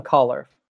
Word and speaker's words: Colfer: Ian Collar. – There you go. Which it sounds like it's very easy to pronounce, but it doesn't look like it's spelled that way.